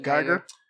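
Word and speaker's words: Guyger: But if I know the story is a cop named, I think it's Amber Geiger. I think that's how you say it. – Geiger.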